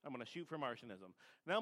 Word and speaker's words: Marcionism: First one is Martianism, which is the one that both Merriam-Webster and Britannica state is the right pronunciation for it.